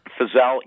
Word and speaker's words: Fizel: So tell us a little bit about, you got the, is it Don Fizel? Is that how you pronounce it? – Fizel, yeah, that's exactly how it's pronounced.